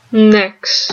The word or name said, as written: nex